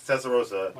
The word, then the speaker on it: Testarossa: Testarossa? – Yes, Testarossa.